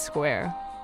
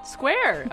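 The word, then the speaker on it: Squier: Oh, I actually, that was the one thing on the Wikipedia page I read. It's Square. – Square.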